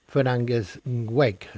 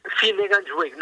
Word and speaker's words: Finnegans Wake: Finnegans Wake, ¿no? No sé si lo he pronunciado bien, doctor. – Finnegans Wake, no, bueno, es que sí, es una palabra irlandesa, no sé nuestra fonética irlandesa que tal va.